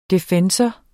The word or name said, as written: defensor